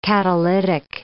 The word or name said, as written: catalytic